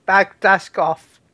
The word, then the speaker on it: Bagdasarov: I don't know how to pronounce your last name. – You actually pronounce it better than a lot of people. – Sweet. – Better than me. – Sweet. – Better than me, Bagdasarov. – No, there's no k. There's no k sound in there.